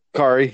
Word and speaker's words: Kari: Is it Kari? Is that how you pronounce the mother's name? – Kari, yeah.